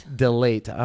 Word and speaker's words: DeLaittre: DeLaittre.